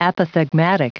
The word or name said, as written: apothegmatic